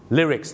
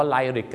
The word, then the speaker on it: lyrics: Lyrics นั่นเองครับหนังเรื่องนี้นะครับต้องขอขอบคุณมากนะครับเพราะก่อนหน้านี้พี่น้องชาวไทยออกเสียงว่าไลริก